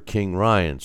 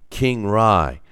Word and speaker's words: KingRy: He says, "Take the name Ryan, not just renounce the first row letter as if you're trying to say the name Ryan, so you know, pronounce it like rye bread. So the name, his nickname is, which is short for King Ryan. So it's pronounced King Rye, not Kingry.